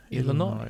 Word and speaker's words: Illinois: Illinois? – Illinois.